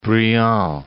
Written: Briand

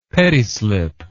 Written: pettislip

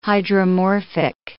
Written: hydromorphic

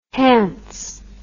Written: hance

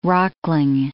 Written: rockling

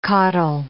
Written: caudle

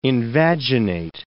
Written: invaginate

